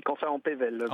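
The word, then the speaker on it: Camphin-en-Pévèle: Face à vous, donc, se trouve qui habite à Canfin en Pevelle. Est-ce que je le prononce bien ? – C'est Canfin en Pevelle.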